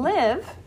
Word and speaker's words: live: Usually the silent E changes the vowel sound, but in live, it's not. It's the short sound.